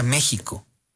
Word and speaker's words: México: México se pronuncia México. Y la historia de por qué se pronuncia México es de verdad muy bella, porque tiene que ver con la evolución del sh.